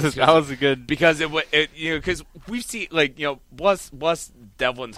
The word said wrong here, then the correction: Delvin: heart, because like he's had promoters fuck up his name so Delvin.